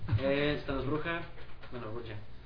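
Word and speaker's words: Brujah: Y la pronunciación es Bruja, ¿no? – Bruja. De hecho creo que es sí, es Bruja.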